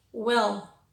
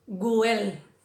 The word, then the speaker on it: well: Well, wasp. Tener cuidado no hacer el g, guel o wasp. No, es una, es well, wasp, good, wound.